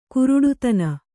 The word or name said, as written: ಗಾರುಡತನ